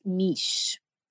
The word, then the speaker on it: Mis: M-I-S, but it's pronounced Mish.